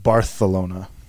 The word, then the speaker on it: Barcelona: I believe it's pronounced Barcelona. – Barcelona? Sí.